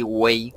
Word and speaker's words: huey: Hasta donde tengo entendido, Tlatoani significa como que orador y wey, que se escribe H-U-E-Y, significa gran.